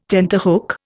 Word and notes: tenterhook: Indian

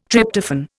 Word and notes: Tryptophan: Indian

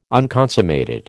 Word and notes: unconsummated: American English

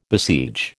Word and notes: besiege: American English

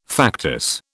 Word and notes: factice: British English

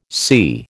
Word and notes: SI: American English

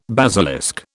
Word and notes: basilisk: British English

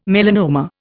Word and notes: melanoma: Indian

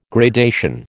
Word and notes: gradation: American English

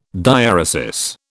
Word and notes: diaeresis: British English

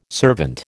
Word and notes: servant: American English

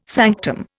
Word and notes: sanctum: Indian

